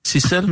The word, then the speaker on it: Zissel: bliver det udtalt sådan lidt fransk. – Cicel mm.